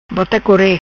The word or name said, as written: Bota Core